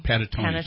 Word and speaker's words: Panettone: I didn't pronounce that right. – But, Panettone. – Panettone. Thank you. – Mm-hmm. – Oh, kind of like the Pentatonics, except it's Panettone.